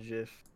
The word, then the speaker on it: GIF: I alternate between GIF and JIF. – I can respect that. – I think both are great pronunciations.